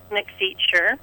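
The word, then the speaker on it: McFeature: I'm as far as Facebook, Facebook is simply under my name, Cynthia McFeacher. And the last name is spelled M-C and then capital F as in Frank, E-A-T-U-R-E.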